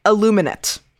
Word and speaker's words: aluminate: but aluminate as in like aluminum, not like illuminate.